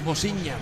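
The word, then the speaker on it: Vozinha: El arquero es Bociña. ¿Está bien pronunciado, Jorge Martín? – Sí, tal cual, con acento portugués. – Sí, señor, Bociña.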